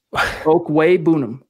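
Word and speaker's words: Okwuegbunam: Albert's name is pronounced Okwebunam.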